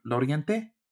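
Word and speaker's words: Laurienté: I think Lorienti was is this right? Loriente? Am I saying that right? – Yeah, yeah, yeah.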